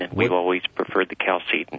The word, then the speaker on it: Chalcedon: Is it Chalcedon or is it Chalcedon? – We pronounce it Chalcedon.